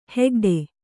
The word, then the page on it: ಹೆಗ್ಡೆ: hegḍe